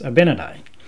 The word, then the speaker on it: Abinadi: or as uh, people who have never been Mormon would call him for the name, they call him Abenadi. But anyway, it's pronounced Abenadi.